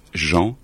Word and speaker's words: Jean: We say Jean Seberg in French, but we pronounce Jean. And it's the American, well, the Anglo-Saxon way of telling this name. Because in French it's Jean, and Jean is only for men.